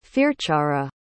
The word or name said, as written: fearchara